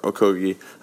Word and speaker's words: Okogie: Okogie, I believe, is the correct pronunciation, out of Georgia Tech, at number 20.